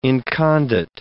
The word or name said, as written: incondite